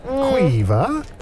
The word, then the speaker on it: Quiva: Quiva. – Pronounce the V. It's a hard V.